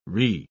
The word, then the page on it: re-: British English